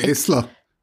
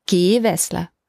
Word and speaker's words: Gewessler: Gewessler. – Also entweder stark auf der ersten Silbe betont, würde ich denken, so Gewessler.